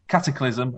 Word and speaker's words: Cataclysm: Catacillin? How would you pronounce that? Can you see the word? Do you know what I'm on about here? – Have you got it up on your screen? – Let me pronounce it. Cataclysm, apologies. And Food Fight.